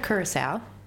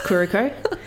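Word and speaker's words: Curicó: Isn't it Curacao? – No, this is not spelled the same way as you're thinking. It's Curico.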